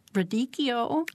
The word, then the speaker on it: radicchio: You could do, I always mispronounce it, radicchio. Is that correct? – I think that's how I've heard it pronounced.